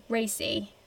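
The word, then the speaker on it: Raci: And yeah, how do you say his name? Paul Racy? – Mm-hmm. I believe so, yes.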